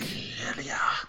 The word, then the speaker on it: Suspiria: Suspiria. – Yes, that's the way to pronounce it.